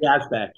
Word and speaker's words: Yazbek: Yazbek.